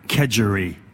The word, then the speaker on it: kedgeree: Un kedgeree.